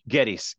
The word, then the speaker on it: Geddes: Geddes.